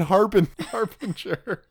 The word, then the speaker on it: harbinger: And it's a soft G in harbinger and not in bringer.